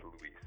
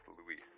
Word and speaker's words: Luis: Yeah, but it's actually the first name is Luis. – I'm sorry. – The first name is pronounced Luis. Luis.